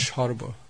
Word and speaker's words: sarva: This sh, that's important. – Вот это sh очень важно. – Sharva.